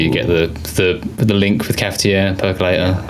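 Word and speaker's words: cafetière: The Cafetiere.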